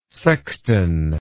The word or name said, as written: sextan